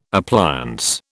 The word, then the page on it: appliance: British English